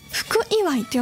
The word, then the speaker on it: 福祝: そしてヒメマスをお供に、千葉のね日本酒をいただくということでね、日本酒のお写真もつけていただいたんですが、福祝いって読むんですね。七福神の福にお祝いの祝いで福祝いというね、千葉のお酒だそうです。